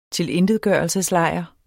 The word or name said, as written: tilintetgørelseslejr